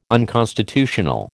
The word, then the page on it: unconstitutional: American English